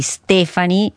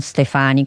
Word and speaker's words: Stefani: Devo ricordarmelo come plurale di Stefano, quindi Stefani e non Stefani, come ho detto prima.